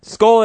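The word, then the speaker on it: Skoal: But Skoll, is that how we pronounce the chewing tobacco? – Yes. – All right. Skoll it is.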